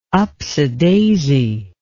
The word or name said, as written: upsadaisy